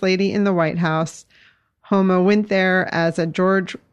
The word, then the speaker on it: Huma: For those of you who don't know, and many people pronounce her name Huma, but it's actually Homa, which I've learned by listening to her say it.